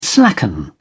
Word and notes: slacken: British English

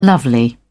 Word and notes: lovely: British English

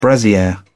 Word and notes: brassiere: British English

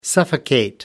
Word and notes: suffocate: American English

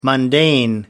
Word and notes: mundane: American English

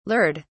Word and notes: lured: British English

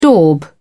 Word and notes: daub: British English

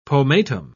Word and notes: pomatum: British English

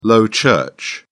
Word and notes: low church: British English

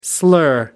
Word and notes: slur: American English

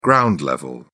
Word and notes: ground level: British English

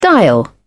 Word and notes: dial: British English

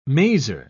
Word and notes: maser: British English

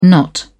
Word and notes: knot: British English

